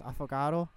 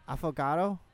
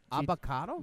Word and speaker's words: affogato: I think it's pronounced affogato. – Would you have? – Affogato. – Avocado? – No, affogato.